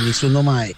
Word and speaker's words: Nessuno mai: Bella, lo ha pronunciado molto benissimo. Nessuno Mai, così se dice.